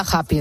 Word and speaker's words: happy: Y luego está happy, donde el problema pues vuelve a ser la H, que lo pronunciamos como una J española.